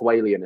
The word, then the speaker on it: Walian: North, I'm North Walian. It's called. North that's, Walian. That's how they say it.